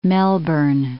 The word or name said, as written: Melbourne